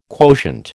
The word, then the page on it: quotient: American English